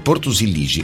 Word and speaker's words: Silixi: Spiagge come Ferasci, Cala Savigu e Porto Silixi sono tranquille anche nei mesi più affollati. Come si legge così, Franco? Silici? Porto Silici, ecco, sì, sono tranquille, ho detto Silixi, vabbè, Silici, ok, perfetto,